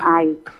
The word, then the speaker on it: Ey: DP tulisan ku EY, merbacanya AI. – Oh, begitu DP Bapak baca, Kang. Oke deh. – Iya. Tulisan EY.